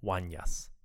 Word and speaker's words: Unas: As you listen, see if you can find Orlando's references to King Unas, which he pronounces as Wanyas.